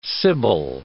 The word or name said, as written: sibyl